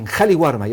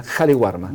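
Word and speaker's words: Qali Warma: ¿Cuántos beneficiarios son en Cariwarma? Ya lo dije bien, ¿eh? Cariwarma.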